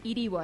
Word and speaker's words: Erivo: Erivo, así se llama. Ella es Elphaba. – Ok. – Y no me corrijan que es Erivo, se pronuncia Erivo.